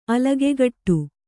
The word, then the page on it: ಅಲಗೆಗಟ್ಟು: alagegaṭṭu